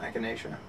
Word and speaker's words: echinacea: Echina. – Is that how you pronounce Echina. – It? Echina. – Wait, it's an echinacea.